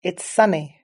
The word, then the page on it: it's sunny: British English